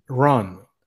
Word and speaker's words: Rohn: se llama Jim Ron. Y se escribe R-O-H-N.